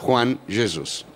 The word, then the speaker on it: Juan Jesus: Juan Jesus, ecco, quindi adesso questo pezzettino qua lo daremo a tutte le televisioni, così tutti i miei colleghi sapranno che si pronuncia Juan Jesus, non Juan.